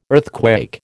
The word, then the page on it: earthquake: American English